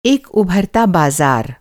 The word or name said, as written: उभरना